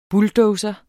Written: bulldozer

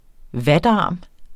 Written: vatarm

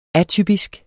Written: atypisk

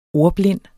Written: ordblind

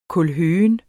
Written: kålhøgen

